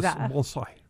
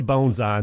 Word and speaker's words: bonsai: Technically, a bonsai. – No, see, but I can't do that. – You can't. Neither syllable is accented, but no, very few people can do that. – Okay, well, good. So I can say bonsai, and they will understand what I'm asking about.